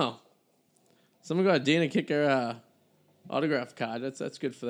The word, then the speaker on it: Kiecker: Dana Kicker. I think it's. D-A-N-A, I think it's spelled K-I-E-C-K-E-R. Dana Kicker.